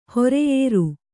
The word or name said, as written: ಹೊರೆಯೇರು